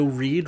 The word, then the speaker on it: Reid: I am on Twitter at Joe Reid.